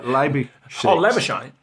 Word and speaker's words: Labuschagne: Labuschagne.